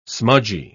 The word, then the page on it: smudgy: British English